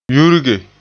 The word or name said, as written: yurge